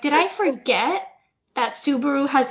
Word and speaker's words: Subaru: Subaru is not, in fact, pronounced to Subaru. – Wait a second. Does Subaru have a silent T at the beginning? – It does not, but for some reason, like, it's spelled with a S, but for some reason I was like, oh, Subaru, like tsunami. Subaru had a T, and I don't know why.